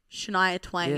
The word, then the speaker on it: Shania Twain: Shania Twain.